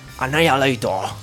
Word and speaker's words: Annihilator: Según el tío Jeff Waters, es Annihilator.